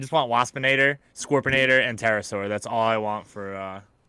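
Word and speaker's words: Terrorsaur: But the way it's spelled is terror, not terra, like a pterodactyl. – Pterosaur was spelled T-E-R-R-O-R. Pterosaur. – I thought it had a P in front of it. – No. Pterosaur, terrorize.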